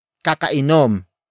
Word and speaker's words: kakainom: Word for word, it's kakainom lang niya ng kape.